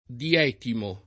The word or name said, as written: dietimo